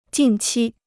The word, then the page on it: 近期: jìn qī